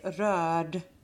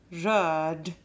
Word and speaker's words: röd: RD, and then you don't really pronounce the R because it's imperfect, and then it's like turn around, and then you say röra, and if it's an imperfect, you say röd, röd.